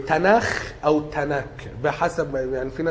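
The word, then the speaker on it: تناخ (תנ״ך): ماشي، يعني العهد القديم كتب اليهود في مسمى اليهود اسمه تناخ. – هو بيتنطق كده.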